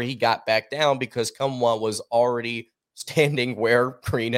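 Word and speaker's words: Nkamhoua: It's Olivier Kumwa, if I'm not mistaken, is how you pronounce his last name.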